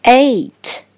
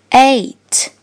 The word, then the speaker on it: eight: 注意第二个字母 e 发 a，follow me a a seven seven 数字八 eight，其中字母组合 g h 不发音，字母组合 e i 发的音是 a。 Follow me. A, A, eight, eight.